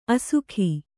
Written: ಅಸುಖಿ

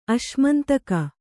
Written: ಅಶ್ಮಂತಕ

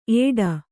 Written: ಏಡ

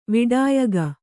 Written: ವಿಡಾಯಗ

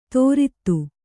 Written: ತೋರಿತ್ತು

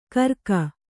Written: ಕರ್ಕ

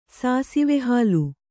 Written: ಸಾಸಿವೆಹಾಲು

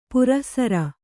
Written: ಪುರಃಸರ

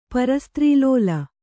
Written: ಪರಸ್ತ್ರೀಲೋಲ

